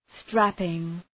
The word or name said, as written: strapping